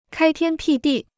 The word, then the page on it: 开天辟地: kāi tiān pì dì